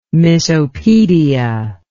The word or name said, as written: misopedia